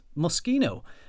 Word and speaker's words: Moschino: it's not pronounced Moschino, it's Moschino. And I was like, oh, I'm going to check this out. So I actually Googled it and found this website which told you how to pronounce designer brands. And it said Moschino is pronounced Moschino.